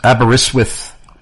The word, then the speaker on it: Aberystwyth: And he taught me how to say this city, and I may still be butchering it because it's been a while since he taught me how to pronounce it correctly. But I think it's Aberystwyth, Wales. And perhaps Mike in Aberystwyth, Wales will correct me if I pronounced it wrong.